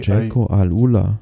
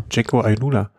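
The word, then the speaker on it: Jayco AlUla: Dann äh, Team, wie spricht man es aus? Ich weiß gar nicht. Jaikui, Jaiko Alula. – Jaiko Alula. Stimmt, das haben wir schon mal besprochen.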